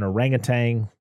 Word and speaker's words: orangutan: King Louie, of course, is an orangutan, which orangutan, or however you pronounce it. – Close enough. – It's not Tang, I guess. There's no G at the end.